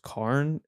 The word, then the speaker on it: Cairn: Yeah, I I can't remember the name of that record particularly, uh, but then there's like uh, like I think it's pronounced Cairn. It's like the Stones.